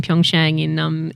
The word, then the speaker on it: Pyeongchang: I've been to the London Olympics in 2012, Rio in 2016, and I'm about to go to the first Winter Olympics in Pyeongchang in February.